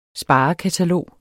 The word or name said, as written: sparekatalog